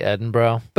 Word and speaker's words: Edinburgh: On our episode on the book Science Fictions, I explain how the Scottish author went to college in Edinburgh, which is actually Edinburgh. But to be clear, it is spelled Edinburgh. But the only reason I even know that it should be pronounced closer to Edinburgh is because there's a famous comedy festival there every year.